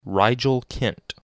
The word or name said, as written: Rigel Kent